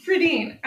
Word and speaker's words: Fredin: Fredin.